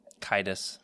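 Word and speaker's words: Caedus: Darth Cadus, or how do you say it? – Kytus.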